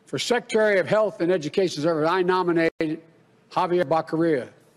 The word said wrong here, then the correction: Javier Becerra: You know, Javier Becerra, excuse me. – It's Javier Becerra. Now, anyone can mispronounce a name. I do it. Anyone can do that.